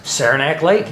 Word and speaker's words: Saranac: Saranac Lake, yeah.